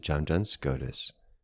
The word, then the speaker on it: John Duns Scotus: So the book is Ordered by Love, An Introduction to Blessed John Duns Scotus by Thomas Ward, available on Amazon and wherever fine books are sold.